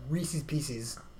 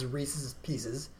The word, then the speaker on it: Reese's Pieces: So you might have thought Reese's Pieces was how it's pronounced. It's Reese's Pieces.